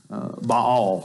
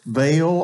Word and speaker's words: Baal: but has to battle these prophets of Baal, which is, by the way, I've said this before, that's the correct pronunciation, but I have heard it and we have said it, Baal, all of my life.